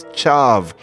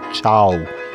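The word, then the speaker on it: ciav: And I don't feel bad because nobody knows Piedmontese outside of the area, but I said that the word for key was chav. But no, that V is pronounced as a W. I should have said chow.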